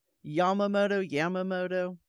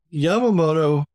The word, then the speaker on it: Yamamoto: Am I saying that right? Yamamoto? Okay. This guy, he's considered like the guy behind the attack on Pearl Harbor, the architect.